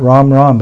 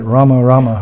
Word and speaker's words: Rama: You have to pronounce it the 32 syllables, not Ram Ram, but Rama Rama Hare Hare.